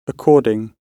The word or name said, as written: according